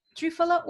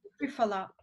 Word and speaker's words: Triphala: Trifala oil, T-R-I-P-H-A-L-A. – Fantastic. Thank you. – Yeah, trifala oil.